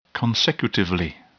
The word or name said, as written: consecutively